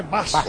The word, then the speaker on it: Bas: No se dice Guas, ¿eh? Se dice Bas.